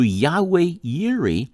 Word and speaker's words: Yahweh Yireh: One of God's Old Testament names is Jehovah Jireh, or more literal pronunciation of the Hebrew, Yahweh Yireh, which means the Lord our provider.